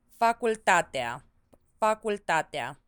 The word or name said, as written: facultatea